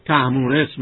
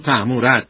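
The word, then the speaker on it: تهمورث: در فارسی معمول ما الان تهمورس میگیم و خیلی کسان هم از معاصران ما نام شخصیشون تهمورس هست و تهمورس تلفظ میکنن، اما اون نگاشت شاهنامه جای خودش رو داره و ما مطابق نگاشت دکتر خالقی مطلق همون تهمورت میخوانیم.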